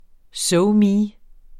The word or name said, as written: SoMe